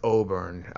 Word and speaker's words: Oberne: I don't really, and I know a lot of people know him personally, but it's a lot of Osbornes in this world. – Yeah, no, I'm sorry, I said his name incorrectly. Just Oberne, no S in his name, but he, you know, he's kind of there's no getting around it.